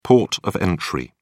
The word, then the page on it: port of entry: British English